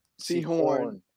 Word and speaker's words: Sehorn: Yeah, like, you say his name was Jason Seahorse? – Sehorn. – Sehorn. Oh, okay.